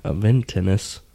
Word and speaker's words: Aventinus: The Aventinus is going to have Aventinus. I keep forgetting the extra syllable.